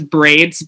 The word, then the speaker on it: Brvidz: The name is, artist name is Braids, but it's spelled B-R-V-I-D-Z.